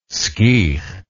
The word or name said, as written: skeech